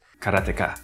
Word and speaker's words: Karateka: I called it Karateka. – But he said there's more of a Japanese way of pronouncing it. So he goes, Karateka. Something like that.